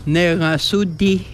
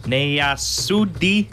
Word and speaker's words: Negasudi: How do you say you are funny? – Negasudi. – Negasudi. – Negasudi. – Negasudi. – Yes, right on. – Negasudi. – That's it.